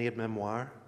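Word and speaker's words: aide-mémoire: So take the leaflet away, use it as a memory, an aid memoir.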